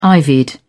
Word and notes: ivied: British English

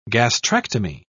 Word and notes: gastrectomy: British English

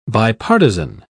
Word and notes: bipartizan: British English

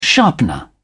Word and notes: sharpener: British English

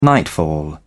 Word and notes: nightfall: British English

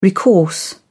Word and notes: recourse: British English

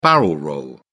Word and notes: barrel-roll: British English

